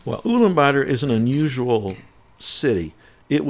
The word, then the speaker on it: Ulaanbaatar: Ulaanbaatar. – Yes. – That is the capital. – Yes. – And I think I said it right. – Ulaanbaatar, yes.